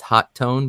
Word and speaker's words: Hotone: It's pronounced Hot Tone, but I call it Hotone because it's Hotone. It's supposed to be Hot Tone, and the T in Hot is supposed to be shared with the T in Tone, but I call it Hotone because it's cooler that way.